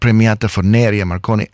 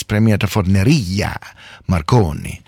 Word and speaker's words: Premiata Forneria Marconi: And I believe I've been pronouncing the name of the band incorrectly. It is not Premiata Forneria Marconi, as I've been saying. It's Premiata Forneria Marconi.